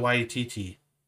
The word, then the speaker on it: Waititi: Taika Waititi. – Waititi? – Waititi. – Waititi. – Yeah, you pronounce all the syllables. Or pronounce all the vowels.